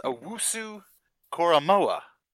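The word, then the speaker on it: Owusu-Koramoah: I think it's pronounced Owusu-Koromoa.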